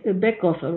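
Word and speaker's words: Baeckeoffe: Right, the Beckhofer, right? Is that how you say it? – Yes.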